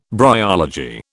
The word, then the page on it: bryology: British English